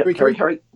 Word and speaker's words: Kerikeri: So if you're at Kerikeri, is that how you say it? – Yeah, Kerikeri.